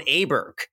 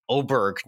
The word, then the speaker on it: Åberg: I think we need to be saying Åberg now. Apparently there's some sort of Scandinavian pronunciation. I think it's Åberg now. I don't love this because it's spelled Åberg, but I think he's Ludvig Åberg from here on out.